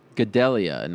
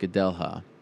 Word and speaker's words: Gadelha: By the way, Joanna, and it's pronounced Gadelha, not Gadelha.